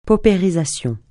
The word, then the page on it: paupérisation: francais